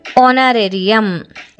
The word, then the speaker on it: honorarium: ಇನ್ನೊಂದು HONORARIUM, ಆನರೇರಿಯಂ, HONORARIUM, ಆನರೇರಿಯಂ. ಇಲ್ಲಿ H silent ಆಗಿದೆ.